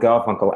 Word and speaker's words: Garfunkel: It's like when I say my name, Adam Garfunkel, as in Simon And. It's a kind of...